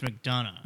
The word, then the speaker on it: McDonough: Not only, these big people like Hillary Clinton, but John McDonahue. Wait, how do you say his name? – I believe it's McDonough.